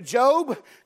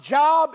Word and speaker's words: Job: A lot of people don't read the book of Job because they think it's pronounced Job, and they won't turn there without a $2,000 signing bonus. You can relax. It's not Job.